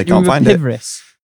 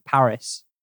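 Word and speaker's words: PVRIS: Do you remember, Pivris? – Do I remember what? – Pivris. How it's P-V-R-I-S, but you're supposed to pronounce it Paris. – Yeah, because it's like an upside down A, I guess.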